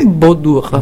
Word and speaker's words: Baudour: Cioè il giovedì 7 aprile c'è una conferenza al Parc Comunale de Bois d'Or, spero che lo pronuncio bene. – Secondo me è Baudour. – Baudour, ecco, merci ma chérie.